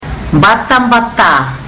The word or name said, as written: batabata